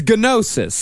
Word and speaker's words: gnosis: Is it gnosis or gnosis? – I think it's gnosis. – Gnosis. – How is gnocchi pronounced? Gnocchi? – Gnocchi. – It's spelled like gnocchi. – The food? – It's gnosis. So let's say gnosis.